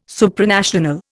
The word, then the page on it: Supranational: Indian